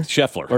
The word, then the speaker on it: Scheffler: I like Adam Scheffley, too. – Yeah. – Which one did I butcher there? – Scheffler? – Scheffler.